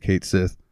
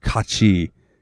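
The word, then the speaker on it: Cait Sith: Why is it Kate Sith? or I'm sorry, if you want to pronounce it correctly, it's like Kachi, because it's like Gaelish Irish or something like that.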